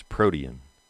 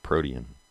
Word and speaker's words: protein: When I was in ninth grade, I had a science teacher who pronounced the word protein as protean. And I don't know. She said you could do it either way, and she preferred protean.